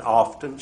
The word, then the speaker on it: often: She said, how do you pronounce O-F-T-E-N? I said, often. She said, there you go again. She said, you need to get your Webster's Dictionary and look it up. That letter T is silent. It's often.